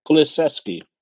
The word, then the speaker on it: Kulusevski: Kulusevski.